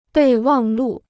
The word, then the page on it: 备忘录: bèi wàng lù